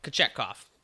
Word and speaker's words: Kochetkov: And then they also have, I have no idea how to pronounce this, Peter Kochtakov? I'm not really sure how. – Pietar or Peter. It's like a Russian Peter. – Peter Kochtakov. – Kochetkov. Yeah. – Okay, Peter Kochetkov.